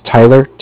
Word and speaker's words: Tyler: And then my name is Tyler, T-Y-L-E-R. – Okay. – And I'm calling with Cardiff. It's spelled C-A-R-D-I-F-F, as in Frank Frank.